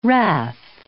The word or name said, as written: wrath